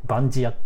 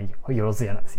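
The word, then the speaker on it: 万屋: はい、あと何だろうな、まあ、何でもやるっていうかね、よろずやっていうね、バンジヤって読むんですけど、まあよろずやなんですよ、バンジヤ、バンジ、そのまま読むとバンジヤなんですけども、まあよろずやって書くんですけど、マンって書いてことって書いてやって書いて、あ、何言ってんだ。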